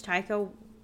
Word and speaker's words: Taika: I looked it up and it's Taika. Fuck.